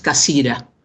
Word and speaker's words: Cassirer: Estoy hablando de Ernst Cassirer, o como lo pronuncian los alemanes, Ernst Cassira.